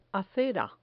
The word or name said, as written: acera